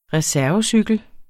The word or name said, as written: reservecykel